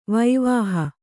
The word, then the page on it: ವೈವಾಹ: vaivāha